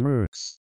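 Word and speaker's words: Merckx: It is pronounced Mercix. Eddie Mercix. Rhymes with jerks.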